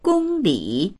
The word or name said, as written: gong1li3